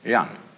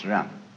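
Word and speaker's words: Ren (仁): In Confucius' philosophy, the fundamental virtue of a human being is called yan spelled J E N for reasons best known to Chinese scholars. Uh, I don't know what they are, but it's pronounced Ren.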